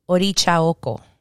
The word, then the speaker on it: Orisha oko: In Spanish, Orisha oko. The S turns into a ch.